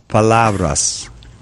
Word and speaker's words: palavras: Duras palavras.